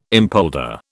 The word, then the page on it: empolder: British English